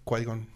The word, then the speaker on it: Qui-Gon: Que es cuando se enfrenta a que es como se enfrenta a Obi-Wan y a, y a Kigon, ah, ¿Sí, no? ¿Cómo se pronuncia? Qui-Gon.